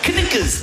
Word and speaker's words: knickers: Of all the letters, why would a K be silent? Knickers.